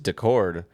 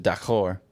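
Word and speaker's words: Daccord: I think it's just Decord, but I pronounce it D'accord, which means okay in French.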